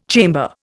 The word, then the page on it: chamber: Indian